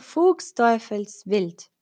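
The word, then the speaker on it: Fuchsteufelswild: Fuchs, ah, Fuchs Teufelswild, pero has pronunciado bien. Gracias, gracias, Chris. Ah, Fuchs, como más fuerte, Fuchs.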